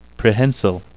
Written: prehensile

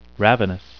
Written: ravenous

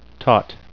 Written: taut

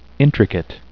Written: intricate

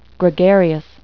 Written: gregarious

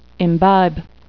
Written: imbibe

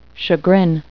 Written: chagrin

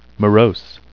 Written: morose

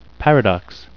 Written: paradox

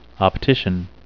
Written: optician

